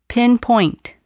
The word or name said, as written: pinpoint